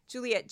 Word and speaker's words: Juliet: I'm on YouTube and Facebook under my own name, Juliet Jeske. Um, Juliet spelled like Romeo and one T, so it's just Julie with a T at the end.